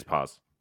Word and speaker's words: Paz: was it Paz? – That's one of them. – Is how you say it? – Paz and Tesh.